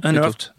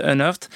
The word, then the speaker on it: Unearthed: Ça s'appelle Sad Hill Unhearted. Et c'est un documentaire. – Unearthed. – Ouais, je suis putain. En écrivant mal le truc, j'ai eu du mal à le reprononcer. Sadil Unearthed.